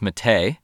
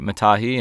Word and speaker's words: Matahi: Well, the one thing that I picked up is that we've been saying Matahi Dollet's name wrong the entire time. It's not Matahi, it's Mate. So sorry, Matahi and everybody else in French Polynesia for the entire surf world offending you yet again.